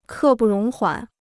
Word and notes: 刻不容缓: kè bù róng huǎn

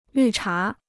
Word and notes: 绿茶: lǜ chá

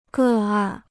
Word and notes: 个案: gè àn

